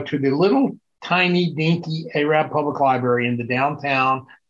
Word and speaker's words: Arab: Arab, that's right. How could I forget? – A-R-A-B, pronounced A long A, Rab, not Arab.